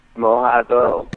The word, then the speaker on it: mojado: Mojado with a D, kind of a TH sound.